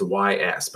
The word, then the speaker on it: Ys: It's called Ys. It's Y-S, but it's pronounced Ys.